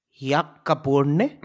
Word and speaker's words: Ya'aburnee: Ya'aburnee.